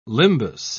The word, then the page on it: limbus: British English